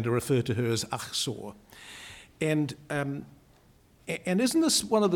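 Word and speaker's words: Achsah: So, for better or for worse, I'm going to suggest tonight that it should be pronounced Achsor, which is probably closer to the Hebrew, but I'm not going to hold you responsible if you don't.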